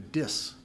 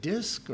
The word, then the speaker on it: diss: Okay, to diss. I never say that right. My son says it. Don't diss them, Dad. And is it disc or diss? Diss, thank you.